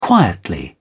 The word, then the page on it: quietly: British English